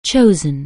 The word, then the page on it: chosen: anglais